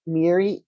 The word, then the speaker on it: Miri: We also take Cash App under the handle $ Miri, M-I-R-I, Jer Falcon, G-Y-R, F-A-L-C-O-N. Once again, that's spelled Miri, Jer Falcon, M-I-R-I-V-Y-R-F-A-L-C-O-N.